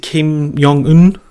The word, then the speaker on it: Kim Jong Un: Is it Kim Jong Un? Is that how you say his name? – I believe so.